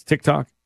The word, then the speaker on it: TikTok: Did I say that right? It's TikTok? Perfect.